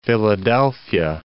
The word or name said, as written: Philadelphia